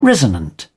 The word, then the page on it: resonant: British English